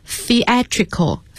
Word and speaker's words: theatrical: theatrical 那么要注意这个单词的发音，它和 theater 是有差别的， theater 是剧院，这个地方是 theatrical， theatrical 发音要注意，把舌头放在牙齿之间才能发得更准确。